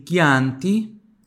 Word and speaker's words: Chianti: Il Chianti. Opa. Bom, o som L você já sabe, né? Mas eu gosto te botar ali, mas você já sabe. CHI são de Ki.